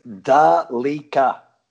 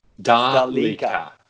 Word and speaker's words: Delica: With the wonders of Google, I've dug up a Japanese ad, and it is Delica. – Delica. Fair enough. Okay. I will adjust my pronunciation from this moment forward. Delica.